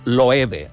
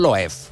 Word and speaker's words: Loewe: O es diu Loewe, no Loef.